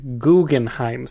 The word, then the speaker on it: Guggenheim: eller Guggenheim som man borde uttala det. Men som ingen gör.